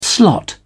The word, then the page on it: slot: British English